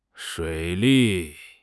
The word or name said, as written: 水利